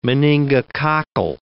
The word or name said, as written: meningococcal